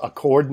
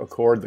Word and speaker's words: Accord: You have this 9-hole course in I believe it's pronounced Accord, New York. It's spelled like Accord the car.